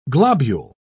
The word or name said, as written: globule